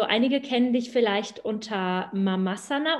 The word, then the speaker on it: Mamasana: Also tatsächlich ähm, ganz korrekt spricht man es so, wie du es eben ausgesprochen hast, aus, äh, Mamasana, ähm, ähm weil es natürlich auch von dem Wort Asana abgeleitet ist, was ja eine Yoga-Position darstellt.